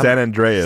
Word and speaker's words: San Andreas: how, San Andreas. San Andreas. That's how you pronounce it.